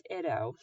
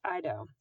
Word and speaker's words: Iddo: His name was Iddo, sometimes pronounced Ido.